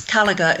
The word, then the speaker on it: Kalaugher: It's Kalaugher. It's Kalaugher.